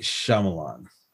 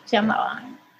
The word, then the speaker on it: Shyamalan: Night Shyamalan. – That's good, Shyamalan.